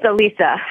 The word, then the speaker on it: Alisa: It's Alisa.